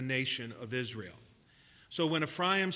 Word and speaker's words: Ephraim: I know most Christian preachers express or pronounce it Ephraim, but being from a Jewish background, it's accurately pronounced from the Hebrew, Ephraim.